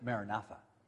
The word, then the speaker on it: Maranatha: There was a saying among the early Christians, Maranatha. Otherwise, some others pronounce it Maranatha.